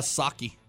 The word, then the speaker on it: Psaki: White House Press Secretary Jen Psaki, that's how you pronounce it, right? – Psaki. – Is it Psaki? – Yeah. – Is it really? – Yeah, the P is silent.